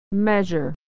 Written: measure

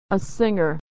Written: a singer

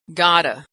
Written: gotta

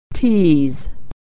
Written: tease

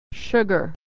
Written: sugar

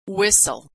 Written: whistle

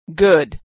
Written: good

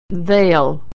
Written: they'll

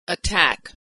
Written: attack

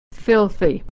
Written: filthy

